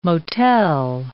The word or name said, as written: motel